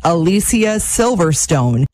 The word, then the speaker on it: Alicia Silverstone: One of her first TikToks also explained that you've been maybe saying her name wrong all of these years. Now, I thankfully knew how to say it properly, and it's Alicia Silverstone.